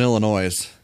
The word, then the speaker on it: Illinois: So we're starting off in Illinois. – They're really sensitive ears. You notice that people say that? Some people actually pronounce the S? – Yeah. – I feel like they're doing it on purpose.